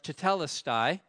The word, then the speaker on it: tetelestai: It's a word that is pronounced tetelestai, to the best of my ability, and it's found on sales receipts, and it's stamped, paid in full, no more debt owing.